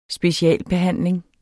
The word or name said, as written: specialbehandling